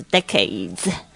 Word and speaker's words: decade: 这个 it's a decade 其实就是讲这个十年，就是十年一段一段的，就是说呢一段十年就是 decade, 很多的十年就是 decades, 好几好几年，好几十年就可以说 decades, 这个要稍微注意一下发音，前面是 the, 然后后面这个是 a 的这个声音 decade, OK, it's a decade, 是怎么样的一个十年呢？